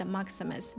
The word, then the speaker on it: Maximus: Обратите внимание на произношение, по-русски мы говорим Максимус, такое у-у, да, в конце Максимус, на английском это Maximus, Maximus, через звук шва, Maximus.